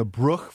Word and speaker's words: Bruch: Yeah, she's playing the Bruch Violin Concerto.